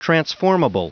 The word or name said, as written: transformable